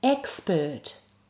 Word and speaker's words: expert: Expert. In expert, the er is in the unstressed syllable. Expert. Nerve.